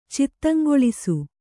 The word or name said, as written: ಚಿತ್ತಂಗೊಳಿಸು